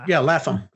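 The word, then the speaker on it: Lapham: How you pronounce his last name? He did Stray Bullets. David Laffam? Laffam or something? – Yeah, Laffam.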